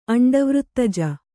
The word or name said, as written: ಅಂಡವೃತ್ತಜ